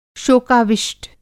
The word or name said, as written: शोकाविष्ट